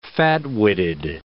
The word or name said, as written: fat-witted